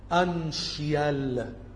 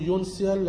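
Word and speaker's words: uncial: الكلمة الإنجليزية دي المفروض بتتنطق أنشيال، أنشيال يعني السي دي بتتنطق شين، أنشيال. في بعض الناس بتقول يونسيال لكن هي المفروض أنشيال.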